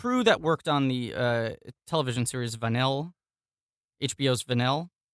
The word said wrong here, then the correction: Vinyl: Uh-huh. Vinyl, he's referring to.